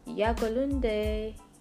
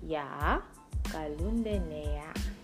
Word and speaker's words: Я голодная: Я голодная. Okay, I'm going to say that slowly. Я голодная.